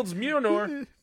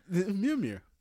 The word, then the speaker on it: Mjolnir: My man Cap wields Mjolnir, which I just totally butchered. – Mjolnir.